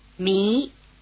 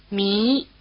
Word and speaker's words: Mi: Mi, Mi, Mi. – Notice that the sound E is spelled with an I, as in Tahiti. Try repeating. – Mi, Mi, Mi.